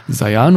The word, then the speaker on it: Sayan: Du hast zum Beispiel mal im Alleingang die Gebirgszüge des Sayan, wird Sayan ausgesprochen? Sayan, ja.